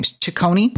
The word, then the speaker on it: Ciccone: So I'm sending along a bottle of Lee Latage from, how do you say Madonna's last name? Ciccone? – Right.